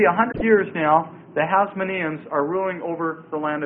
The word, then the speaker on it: Hasmoneans: And then there's one final name that I'd like to leave you with today, and that's the title Hasmoneans. The term Hasmoneans. And the period of Hasmonean rule. Hasmoneans, that's spelled H-A-S-M-O-N-E-A-N-S.